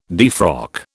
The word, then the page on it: defrock: British English